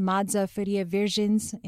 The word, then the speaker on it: Madza Farias Virgens: Today, I am joined by anthropologist Madza Farias Virgens in the Department of Anthropology.